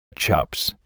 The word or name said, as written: chops